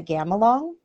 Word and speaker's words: gamelan: So we took a college semester, lived on a ship, traveled the world, and we took a Was it the gamelan? Is that how you pronounce Yeah.